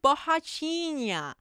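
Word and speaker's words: Borrachinha: I totally butchered his last name. I'm so sorry. I'm going to try this again. Paolo Bahachina.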